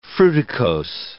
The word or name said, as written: fruticose